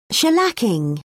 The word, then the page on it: shellacking: British English